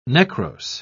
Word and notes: necrose: British English

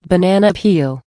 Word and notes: banana peel: American English

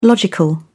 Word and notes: logical: British English

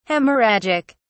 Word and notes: hemorrhagic: British English

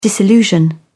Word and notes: disillusion: British English

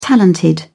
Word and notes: talented: British English

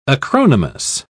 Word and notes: acronymous: British English

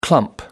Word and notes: clump: American English

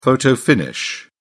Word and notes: photo-finish: British English

